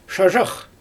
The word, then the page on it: ʃaʒax: Xyzyl